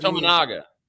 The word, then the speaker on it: Tominaga: Tominaga. – Tominaga.